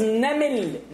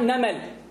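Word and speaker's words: نمل: The word is namil. Namil, not namal. Namil, namil. Namil, which is ants.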